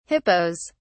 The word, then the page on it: hippos: British English